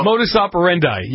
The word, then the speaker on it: modus operandi: modus operandi, yes. – Yeah, heard a lot, modus, in my career. – So you'll edit that out. This is the modus operandi.